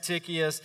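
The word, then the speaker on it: Tychicus: no, it's not pronounced that way. We're going to pronounce it Tychius.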